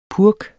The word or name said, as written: purk